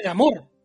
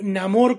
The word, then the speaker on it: Namor: Una, he visto varios gringos decir que lo iban a pronunciar como Namor, como amor en español, hasta que lo escucharan como Namor, así que ni siquiera entre los gringos está saldada la cuestión.